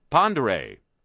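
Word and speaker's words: Pend Oreille: Dear Harry, Pondere is pronounced Pondere.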